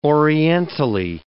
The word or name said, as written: orientally